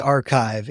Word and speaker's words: arXiv: Yes, academia is still publishing, and I'm going to butcher the name, ARVIX. – Archive. It's spelled ARXIV, but pronounced archive.